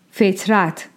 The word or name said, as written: Feṭrat